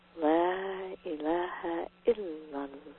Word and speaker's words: La ilaha illallah: It's La ilaha illallah. We're going to emphasize the ill of the third syllable there.